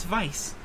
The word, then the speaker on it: Weisz: Even though it's spelled with a W, it's Weisz.